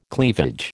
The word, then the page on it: cleavage: American English